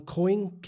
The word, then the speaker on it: Koenig: So this author named John Koenig, K-O-E-N-I-G, Koenig, I think is how Birgit helped me pronounce the German name.